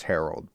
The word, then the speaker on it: Herold: My last name is Harold, but it's spelled H-E-R-O-L-D, and, like, no one spells it like that.